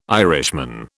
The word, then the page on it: Irishman: British English